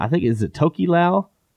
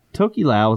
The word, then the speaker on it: Tokelau: Is it Tokelau? – I think that's how you pronounce it. – Tokelau is a Polynesian word meaning north wind, or later to be well known as wet fart.